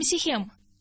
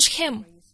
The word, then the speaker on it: Шхем: Первый он построил в городе, который мы называем Сихем, но Шхем. Именно так нужно правильно говорить.